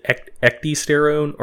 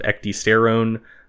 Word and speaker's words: ecdysterone: do you pronounce the D? Is it a hard D or a soft D? Is it ecdysterone or ecdysterone? – I think you pronounce it, but I've okay. mispronounced like 7 or 8 words this podcast. – Yeah, so we're going with the hard D on this one.